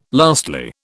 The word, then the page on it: lastly: British English